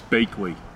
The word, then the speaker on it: Baechle: It's Bakley. – Oh! – Yes. But you don't spell it that way.